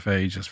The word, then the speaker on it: phages: We talked very early on in our series about, are they pronounced phages? – Phages, yeah, absolutely. – P-H-A-G-E-S. – Yes, that's right.